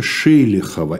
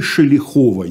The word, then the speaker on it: Шелихова: Его невестой оказалась дочь Григория Ивановича Шелихова. Или Шелихова. Я встречал и такое ударение, и то, такое.